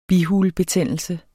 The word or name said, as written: bihulebetændelse